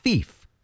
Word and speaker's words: Phoef: P-H-O-E-F. It's pronounced Fief.